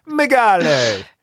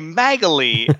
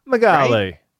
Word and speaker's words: Magale: Magale. – I kept trying to say Magali, and I'm like, that's Italian. – That's Italian. No, it's not. – Anna Magali. – Yeah. Magale.